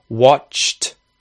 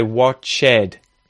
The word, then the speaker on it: watched: So they're adding in this e or e vowel sound, to which adds a syllable, so it changes the way that the word sounds for native speakers. Watched, watched, watched. Evolved, evolved. No, it's evolved.